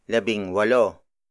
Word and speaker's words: labing walo: This is another exception to the consonant as the first letter rule. While walo begins with a consonant, the letter W sounds like a vowel. So the ng in labing is retained, and while W has a vowel sound, it's still technically a consonant, so the hyphen isn't needed.